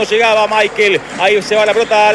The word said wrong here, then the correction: Miquel: ¿Michael o Miquel? Nicolás Álvarez, usted que es especialista en nombre. Se escribe Miquel. ¿Cómo le digo? – Decile Miquel.